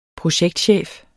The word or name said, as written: projektchef